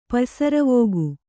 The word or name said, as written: ಪಯ್ಸರವೋಗು